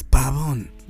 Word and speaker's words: Pabon: It sounds like you're saying cabron, but it's not that, it's babon.